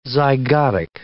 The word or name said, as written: zygotic